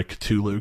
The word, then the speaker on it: Cthulhu: I'm sorry, Cthulhu, because that's technically how you pronounce it.